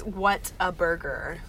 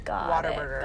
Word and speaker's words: Whataburger: It's Whataburger. – Okay. – That's, like, what the spelling is, but when you're a Texan, you pronounce it like water. Whataburger.